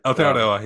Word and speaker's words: Aotearoa: One thing with listening to, like, Thomas's podcast with the history of Aotearoa. – New Zealand. – New Zealand, yeah. did I even pronounce that right? – Aotearoa, yeah, you did.